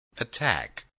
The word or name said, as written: attack